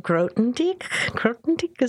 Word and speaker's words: Grothendieck: And then you have Grothendieck, is that how you pronounce it? – Ach, yeah.